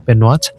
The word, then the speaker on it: Benoit: Y le dio un mensaje la Virgen a esta niña que se llama Benoit, que así se pronuncia en francés, Benoit, y le dijo que la siguiera a una capilla que estaba abandonada.